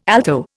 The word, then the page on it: alto: Indian